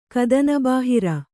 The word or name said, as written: ಕದನಬಾಹಿರ